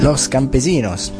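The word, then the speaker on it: Los Campesinos!: Los Campesinos, che vanno pronunciati così perché c'è il punto esclamativo alla fine.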